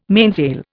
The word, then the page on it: mainsail: Indian